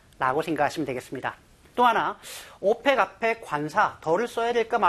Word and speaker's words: OPEC: 조직의 이름, 기관의 이름을 약자로 이렇게 네 단어 이상을 쓸 때 발음대로 오펙 이라고 읽으면 OPEC가 아니고 OPEC 발음대로 읽으면 덜을 쓰지 않고요. OECD 철자 하나하나를 끊어 읽으면 덜을 씁니다.